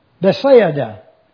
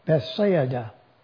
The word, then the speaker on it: Bethsaida: But the word Bethsaida, I decided I would put it out here for you so you could pronounce it correctly. Bethsaida.